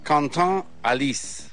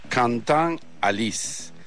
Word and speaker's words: Quentin Halys: Quentin Halys, así dice que se pronuncia su nombre. Quentin Halys.